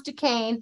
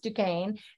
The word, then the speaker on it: Duquesne: It's pronounced Duquesne. Thank you. I admitted I didn't know how to say it. Thank you for telling me it's pronounced Duquesne.